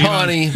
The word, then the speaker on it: Tawine: It's T-A-W-I-N-E. – Okay. Mm-hmm. All right. So when you see Tawny, but T-A-W-I-N-E. – Pronounce Tawny. – The I is silent. That's what I tell people.